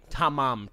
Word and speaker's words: Tamam: And everywhere that phrase shows up in this case, it is spelled correctly. Tamam. T-A-M-A-M. Okay? – Okay. – But for some reason, it is widely known as Tamam with an N because,